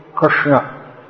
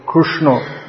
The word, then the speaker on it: Krishna: So it's pronounce like Rit Rit Krishna, Nrsimha. Although in some parts of India it's pronounced like Ru, so as the Oriya say Krishna, Krishna. But it's considered standard pronunciation.